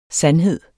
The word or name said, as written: sandhed